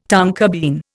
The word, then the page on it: tonka bean: Indian